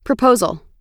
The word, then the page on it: proposal: American English